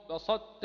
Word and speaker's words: بسطت: وفي سورة المائدة في قوله: بسطت إلي يدك. أخبر الناظم بأن أو نبه علينا بأن نبين صفة الإطباق في حال نطق هذه الطاء من هاتين الكلمتين.